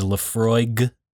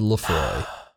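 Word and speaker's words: Laphroaig: Of the other whiskey is Lefroig with the G. – And I said Lefroy.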